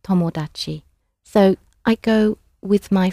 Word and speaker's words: tomodachi: The word for friend in Japanese is tomodachi, tomodachi. Maybe you've got a friend called Tom, who made an odd achievement, tomodachi. How do you say I go? – Ikimasu.